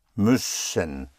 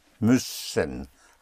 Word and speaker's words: müssen: M-U umlaut, put the two dots over the U, which gives you the Ü sound, where you have to whistle the E. Müssen, müssen.